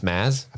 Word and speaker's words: Mads: Do you know you don't pronounce the D? That's what people on TikTok are telling me. In Mads Mikkelsen. – Oh, it's Maz? – Apparently.